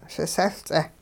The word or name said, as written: sisehL-ts'eh